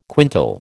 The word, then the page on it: quintal: American English